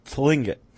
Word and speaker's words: Tlingit: and many members of the, was that Lingit? Is that how it's pronounced? The Lingit tribe? – Tlingit. – I think the T might be silent.